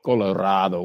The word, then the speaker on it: Colorado: Colorado City, as the locals call it, Colorado would be the correct pronunciation.